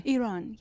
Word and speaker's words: Iran: Iran, yes.